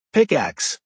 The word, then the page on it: pickax: American English